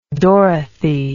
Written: dorothy

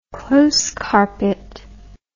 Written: close carpet

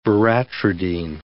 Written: veratridine

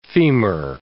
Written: femur

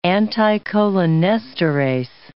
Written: anticholinesterase

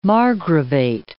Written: margravate

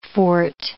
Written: forte

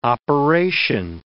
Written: operation

